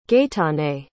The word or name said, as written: gaëtane